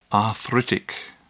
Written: arthritic

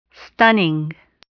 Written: stunning

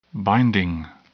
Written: binding

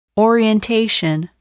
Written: orientation

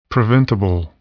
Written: preventable

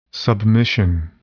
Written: submission

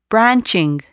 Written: branching

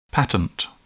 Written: patent

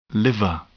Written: liver

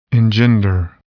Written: engender

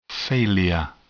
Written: failure

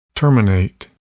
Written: terminate

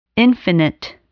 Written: infinite

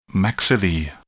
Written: maxillae

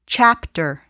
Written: chapter

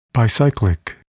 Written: bicyclic

